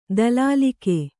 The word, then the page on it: ದಲಾಲಿಕೆ: dalālike